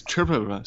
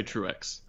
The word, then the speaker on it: Truex: Actually, the correct pronunciation is Truex, so great job. Most people say Truex. – Okay. – Like Truex. And it's French, and that's not the right way to pronounce it, but it is when you're raised in New Jersey.